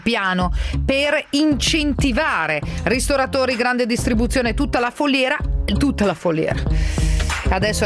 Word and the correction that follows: filiera: arriverà l'SMS, non si dice foliera, si dice filiera.